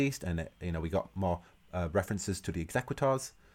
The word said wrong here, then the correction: Executors: Executors, how do we say it, Katie? – Executors, like business executors.